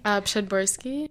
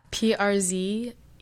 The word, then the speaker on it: Przedborski: Przedborski. – I feel like half of those letters were silent, so. – Yeah, the PRZ together does like a PSH sound.